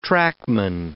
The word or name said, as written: trackman